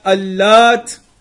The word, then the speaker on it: Allat: One of the explanations is that Allat, if you pronounce it lightly without a shadda, Allat, then it was a stone that had certain markings on it.